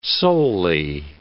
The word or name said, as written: solely